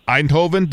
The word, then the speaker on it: Eindhoven: Now, your home city, if I'm not mistaken, correct me if I'm probably going to say it wrong, Eindhoven, did I say that correct? – Yeah, that's good.